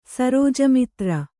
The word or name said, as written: ಸರೋಜಮಿತ್ರ